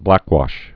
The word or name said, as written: blackwashed